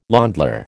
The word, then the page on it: ländler: American English